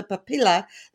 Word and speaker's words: papilla: It says Roberto picks up a chick to give me another chance to play spot the papilla, the proper name for a rooster's sex organ. I don't even know if I pronounced that right. Tove, did I? Yeah, good job.